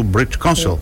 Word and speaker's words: British Council: Instituto British Council.